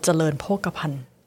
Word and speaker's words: เจริญโภคภัณฑ์: Uh, it's called เจริญโภคภัณฑ์哇，果然很难念。